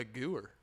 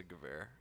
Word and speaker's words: Gewehr: Is that how you pronounce it? – What the fuck? – I thought it was a gewer. – It's a gewehr. – Gewehr. – You pronounce it gewehr.